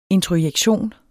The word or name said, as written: introjektion